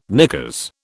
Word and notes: knickers: British English

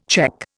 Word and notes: cheque: Indian

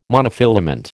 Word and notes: monofilament: American English

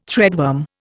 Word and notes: threadworm: Indian

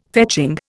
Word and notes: fetching: Indian

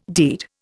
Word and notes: deed: Indian

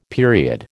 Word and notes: period: American English

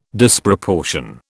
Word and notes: Disproportion: British English

